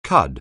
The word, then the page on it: cud: anglais